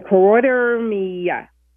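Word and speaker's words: choroideremia: Carotidermia. – You were close. It's carotidermia.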